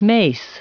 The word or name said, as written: mace